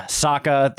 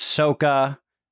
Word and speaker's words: Sokka: Uh, Sokka, they pronounced Soka.